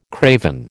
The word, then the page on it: craven: American English